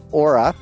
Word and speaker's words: Oura: It's called the Aura, or it's spelled O-U-R-A.